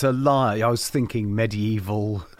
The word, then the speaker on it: LYR: How do you pronounce it? I say liar.